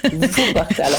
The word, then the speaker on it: Wuppertal: Wuppertal avec W, avec V.